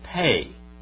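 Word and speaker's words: Pay (פא): Then we have the word Pay. There's a final Pay and a Pay in the word, and that is like P and what? P and PH.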